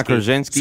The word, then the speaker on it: Krasinski: John Krasinski.